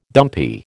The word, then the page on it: dumpy: American English